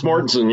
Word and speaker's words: Mortensen: So we have the pleasure tonight of talking with Chris Mortensen, who, did I say that right? I tried to pronounce it. – Yeah, Chris Mortensen, yep. – Okay, so you're a lazy Utah speak. Okay, Mortensen, got it.